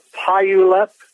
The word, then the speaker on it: Puyallup: It's called Puyallup, P U Y A L L U.